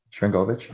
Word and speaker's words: Sharangovich: Sharangovich, I'm gonna say it right this year. – Yeah, you said it right.